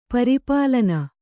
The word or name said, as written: ಪರಿಪಾಲನೆ